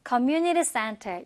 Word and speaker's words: community: 중간에 T 발음은 얼로 약화가 많이 돼서 community center 이렇게 들렸습니다.